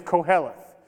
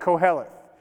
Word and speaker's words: koheleth: The first Hebrew word is transliterated on the screen, it's koheleth. Okay, it depends on what you're reading. It may just end with the T. A lot of them end it with a TH, kind of pronounced like that, koheleth.